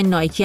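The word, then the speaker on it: Nike: برنده معروف ورزشی نایکی هم اسمش رو از همینجا برداشته، حالا با تلفظ انگلیسیش.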